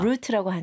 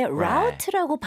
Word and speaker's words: route: 저는 개인적으로 아하. root라고 하는데 route라고 right. – 발음하는 사람들도 있습니다.